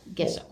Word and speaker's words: gesso: A 31 across, painter's coat was gesso. – Gesso. – Or gesso, thank you.